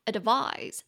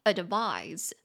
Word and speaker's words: advise: advise somebody to do something。那个建议这个动词啊，它念作 advise。 advise，它是动词，所以它后面的那个发音大家有听到吗？是 z z advise。